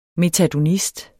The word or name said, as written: metadonist